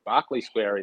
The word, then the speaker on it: Berkeley Square: I think I'm a bit confused whether it's Berkeley Square or Barclay Square because it's spelled Berkeley, but Matt Hill called it Barclay Square in the Exford Plate, and I do trust Matty.